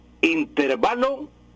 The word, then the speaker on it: intervalo: Intervalo es una palabra grave, lleva acento en la penúltima sílaba, pero no lleva tilde.